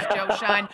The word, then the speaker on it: Schoen: Nobody knew it was Joe Schoen.